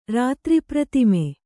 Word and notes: ರಾತ್ರಿಪ್ರತಿಮೆ: Kannada

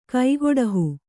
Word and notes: ಕೈಕೊಡಹು: kai koḍahu

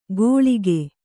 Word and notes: ಘೋಳಿಗೆ: ghōḷige

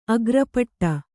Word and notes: ಅಗ್ರಪೀಠ: agrapīṭha

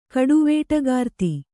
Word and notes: ಕಡುವೇಟಗಾರ್ತಿ: Kannada

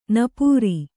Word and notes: ನಪ್ಪುರಿ: nappuri

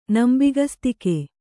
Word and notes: ನಂಬಿಕಸ್ತಿಕೆ: kannada

